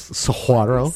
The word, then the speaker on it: saguaro: Like I said, a 6 foot tall saguaro cactus. Saguaro? – Saguaro. – Sounds delicious. – It's like saying curgeon. – Don't pronounce the G.